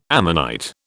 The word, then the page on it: ammonite: British English